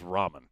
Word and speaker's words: ramen: Who said that he had always pronounced ramen noodles Raymond noodles. – Yeah, he did, and he felt attacked. – Yeah. – Yeah. – I couldn't tell if that was a joke tweet or not. – No, it was dead serious. – I've never heard it pronounced anything other than ramen. I mean, it is ramen.